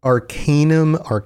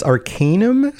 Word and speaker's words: Arcanum: I always pronounced Arcanum, but it's Arcanum.